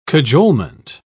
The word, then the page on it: cajolement: British English